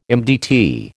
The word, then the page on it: MDT: American English